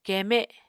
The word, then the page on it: keeme: Apatani